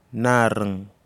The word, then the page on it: naring: Kwamera